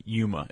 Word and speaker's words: Yuma: And for those of us, for those who really pay attention to the podcast, there is no H on Yuma. It actually is pronounced Yuma.